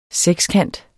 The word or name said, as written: sekskant